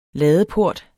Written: ladeport

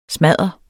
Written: smadder

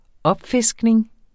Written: opfiskning

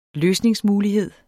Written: løsningsmulighed

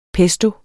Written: pesto

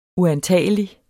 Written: uantagelig

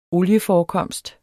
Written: olieforekomst

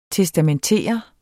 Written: testamentere

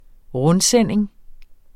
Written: rundsending

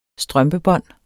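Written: strømpebånd

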